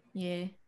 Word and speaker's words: Ye: Ye or whatever. – Ye. – Yeah. – Ye. – Yeah. I saw that. Yeah. My dad was like, "Did you know Kanye West wants to be called Ye, or whatever?"